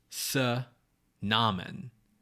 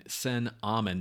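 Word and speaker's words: Ankhesenamun: Namen is not the syllable at the end. It's Amen, Sen Amen, not Se Namen.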